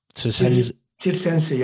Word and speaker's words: Tirsense: But then you made the move to Aguan, and how do you pronounce this one? To Tirsense, yeah.